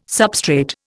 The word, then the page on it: substrate: Indian